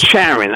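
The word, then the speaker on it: Charen: Outspoken conservative journalist Mona Charon, is that correct? I always seem to pronounce these names wrong, but let's. – It's Charon, I believe it's pronounced.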